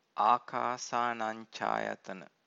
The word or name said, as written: akasanancayatana